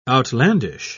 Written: outlandish

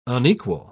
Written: unequal

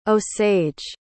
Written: osage

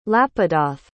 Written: lapidoth

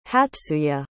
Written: hatsuye

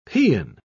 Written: paean

